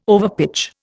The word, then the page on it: overpitch: Indian